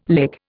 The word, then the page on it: lick: Indian